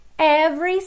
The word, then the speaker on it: every: Every step of the way, então every, esse E do meio não é pronunciado.